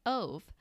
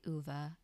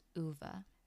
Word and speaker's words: Ove: So, the entire time that I saw this book sitting on the shelves, I, in fact, thought that you pronounced his name Ove. Fortunately, I read the audiobook, so I was quickly corrected. His name is actually Uva. So, it's actually A Man Called Uva.